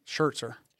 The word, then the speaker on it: Scherzer: I think I don't know why I can't ever say his name. Scherzer. – There's no T.